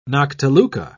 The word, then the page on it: noctiluca: British English